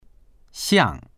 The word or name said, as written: xiang4